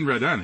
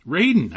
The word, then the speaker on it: Radin: So, hey, welcome, Dean Radin. It's great to have you back. – Glad to be here, Kevin. And my last name is pronounced Radin. – Radin.